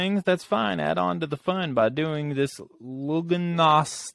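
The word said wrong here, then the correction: Lughnasadh: It's pronounced Lugnasa.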